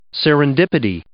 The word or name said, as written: serendipity